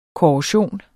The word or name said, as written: korrosion